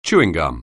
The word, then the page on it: chewing gum: English